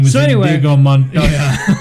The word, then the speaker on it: Indigo: I-N-D-I-G-O.